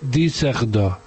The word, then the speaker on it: Düsseldorf: Es que Düsseldorf lleva la Düsseldorf, que se escribe, lleva dos puntos en la U. Y entonces dice Düsseldorf.